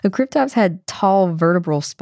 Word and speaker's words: Cryptops: And now onto our dinosaur of the day, Cryptops. I've also heard it pronounced Creeptops.